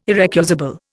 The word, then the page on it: irrecusable: Indian